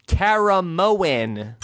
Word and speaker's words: Caramoan: And she says that this season is pronounced Survivor Kara Moen. Two syllables, not Kara Moen, Kara Moen.